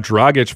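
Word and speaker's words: Dragić: You have Dragic for, hey, I said it right. You have Dragic for maybe this one season.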